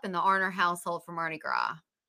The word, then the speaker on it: Mardi Gras: And today is March 1st, Mardi Gras, right? – Yeah, that S is silent, huh? – Yeah. Yes. Okay. Like Illinois and Des Moines.